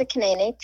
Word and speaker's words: Kaneene: So Tessa Kanene, T-E-S-S-A, last name K-A-N-E-E-N-E.